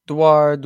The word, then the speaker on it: Duar: Duar, yeah. – I like the French roll to it.